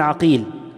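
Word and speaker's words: عَقيل: حصل الاختلاف في الآباء من حيث النطق فقط، زي مثلا محمد بن عقيل بفتح العين، محمد ابن عقيل، ومحمد ابن عقيل بضم العين.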